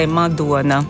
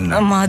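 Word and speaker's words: Madona: Sim, porque na Letónia nós lemos tudo de forma como está escrito, com poucas exceções, como O, lê-se Ua. Então, no caso de é Madonna. – Madonna.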